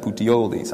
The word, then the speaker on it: Puteoli: I think is how you say that, Puteoli.